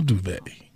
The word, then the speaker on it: duvet: A duvet. A duvet. It's actually my duvet. What's the most you ever paid for a duvet? – Don't say duvet. It's D-U-V-E-T. The T is silent. Duvet. – Yes. – Duvet. So why didn't they just spell it with a V-A-Y? – Well, it's French.